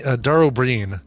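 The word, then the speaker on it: Dara Ó Briain: It's Dara O'Brien, but it's spelled B-R-I-A-I-N, I think.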